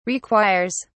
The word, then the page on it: requires: British English